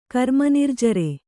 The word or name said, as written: ಕರ್ಮನಿರ್ಜರೆ